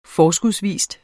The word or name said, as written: forskudsvis